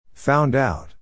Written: found out

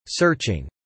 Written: searching

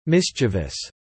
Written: mischievous

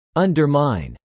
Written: undermine

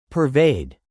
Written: pervade

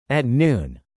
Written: at noon

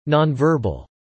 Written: nonverbal

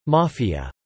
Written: Mafia

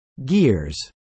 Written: gears

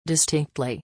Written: distinctly

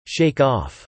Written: shake off

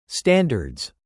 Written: standards

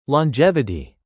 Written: longevity

